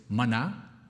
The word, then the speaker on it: manna: By the way, I was told that the proper pronunciation is manna, because the translation is, what is it? So it's like, whatchamacallit? So it's manna, right?